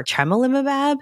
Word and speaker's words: tremelimumab: The good thing is that the CTLA-4 inhibitors, if you see ipilimumab or tremelimumab, am I saying that right, Anu? – That's absolutely right.